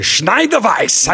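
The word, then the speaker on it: Schneider Weisse: You've got to say it like the German, Schneiderweiss.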